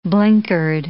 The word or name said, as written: blinkered